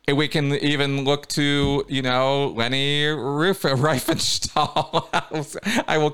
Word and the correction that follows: Riefenstahl: Remember, like reefer, like smoke a reefer. – Reefer, okay. Riefenstahl. There we go. Riefenstahl.